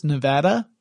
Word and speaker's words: Nevada: I am in Las Vegas, Nevada, since that's the correct way it's pronounced, I've learned.